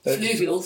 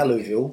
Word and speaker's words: fluvial: Fluvial. Fluvial is soils that are close to rivers. Fluvial. Fluvial, yeah. Um, I think I'm pronouncing Not alluvial, it.